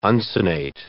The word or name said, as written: uncinate